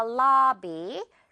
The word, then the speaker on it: lobby: I'll meet you in the lobby. 로비 아니고요. 자, 발음 주의하면서 듣고 따라해 주세요. I'll meet you in the lobby.